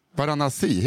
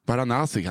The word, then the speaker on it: Varanasi: Varanasi heter det, så Varanasi i Indien. Varanasi. – Varanasi kanske.